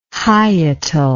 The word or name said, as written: hyetal